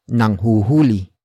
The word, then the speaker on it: nanghuhuli: For example, nanghuhuli sila ng kulisap.